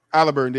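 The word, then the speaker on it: Haliburton: Talking about Halliburton, there you go. That's how you say it.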